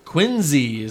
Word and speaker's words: Quincy: Quincy is the way they say it out in New England.